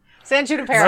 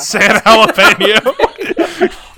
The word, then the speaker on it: San Junipero: San Junipero. – Not San Jalapeno.